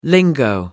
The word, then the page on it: lingo: American English